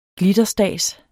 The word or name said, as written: glitterstads